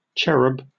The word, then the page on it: cherub: British English